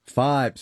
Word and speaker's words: Fybe: Am I saying this right? Fiber? – Fibe. – Sorry. – Sierra. I believe. Yeah. Yes. F-Y-B-E. – F-Y-B-E. Fibe.